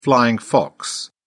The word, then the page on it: flying fox: British English